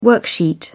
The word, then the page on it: worksheet: British English